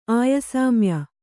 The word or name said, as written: ಆಯಸಾಮ್ಯ